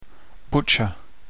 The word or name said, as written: butcher